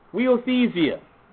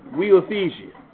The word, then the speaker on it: huiothesia: Now the Greek word that Paul uses in these two verses, brethren, is weothesia. Nice Irish word. Weothesia. It is spelled H-U-I-O-T-H-E-S-I-A.